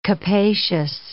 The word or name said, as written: capacious